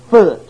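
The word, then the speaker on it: Phut: Now, I didn't know if that was Put or Pahut or Foot or Foot. And I looked it up in my Strong's Concordance, and it's pronounced Foot. F-U-T.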